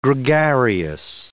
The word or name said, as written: gregarious